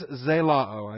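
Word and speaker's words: Zelao (ζηλόω): Z-E-L-O-O. Zelao. You got your short O followed by your long O. Your Omicron Omega ending. Zelao. Z-E-L-O-O.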